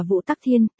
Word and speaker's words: Võ Tắc Thiên: Sau đó thì sao, Võ Tắc Thiên, còn được đọc là Vũ Tắc Thiên,